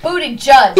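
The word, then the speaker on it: Buttigieg: Somebody said that's not how you say his name, Booty Gig. – What? You're kidding. That's not how you say his name? – That's not how you say his name? – I like it better that way. – What is it? – It's Booty Gig. – Oh. – I can't help it. Hey, look. – Booty Judge.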